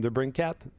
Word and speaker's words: DeBrincat: When everybody was waiting for Keller, Ishir, uh, Debricat. Uh, how do you say his name? Debrincat yep.